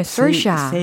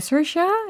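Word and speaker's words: Saoirse: 근데 서샤. – 세오시아. – 서샤. – Yeah, 서샤 이렇게 발음을 한다고 알려주더라고요.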